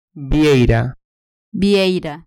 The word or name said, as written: vieira